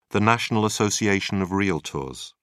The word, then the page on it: National Association of Realtors: British English